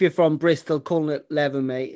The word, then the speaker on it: Leven: I think I said Levin the other week to a to a Scotsman up there, and he said um, I was saying it wrong. I think it's, I think it's pronounced Leven, but I'm not too sure.